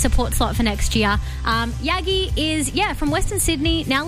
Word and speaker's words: Yagki: Her name is Yagi. The K is silent. Y-A-G-K-I.